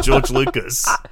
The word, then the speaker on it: George Lucas: George Lucas. I hope I'm saying that right, Lexi. – Yes. It's based on the creator of Star Wars himself, Mr. George Lucas.